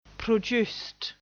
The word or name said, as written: produced